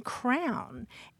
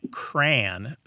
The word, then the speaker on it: crayon: And then there, then there are people who say cran, sounds like rhymes with the first part of cranberry, mm-hmm. – rhymes with man.